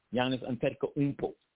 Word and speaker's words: Giannis Antetokounmpo: Let me see if I can try to pronounce it like the brothers would say. Giannis Antetokounmpo. Giannis Antetokounmpo would be something like how they would pronounce it in the context, because the U is like two O's.